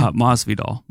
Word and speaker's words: Masvidal: Masvidal.